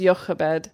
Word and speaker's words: Jochebed: The name of Amram's wife was Jochebed. – Yeah. – Jochebed. I know this name and I heard it pronounced Jochebed.